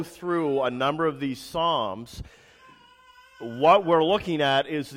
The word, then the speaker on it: Psalms: Now, the book of Psalms is spelled with a P. It's P-S.